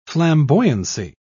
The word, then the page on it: flamboyancy: British English